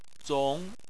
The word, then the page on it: zong: 2